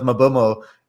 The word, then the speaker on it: Mbeumo: The reason he doesn't want to pronounce Mbeumo is because I corrected him backstage when he called him Mbueno. And yeah. Please, I wanted to make a point. Look, the E is before the U, and Bueno means good in Spanish.